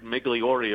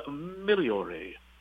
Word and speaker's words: Migliore: It was always Angel Cordero Jr. And it wasn't Richard Migliore. It was Richard Migliore. Because that was the proper Italian pronunciation,